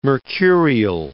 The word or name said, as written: mercurial